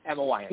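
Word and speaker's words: Weyant: Am I pronouncing her last name right? – Yeah, Emma Wyatt.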